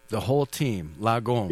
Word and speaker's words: lagom: Lagom? Am I saying that right? – Lagom, yeah. Actually, the word lagom comes from the whole team.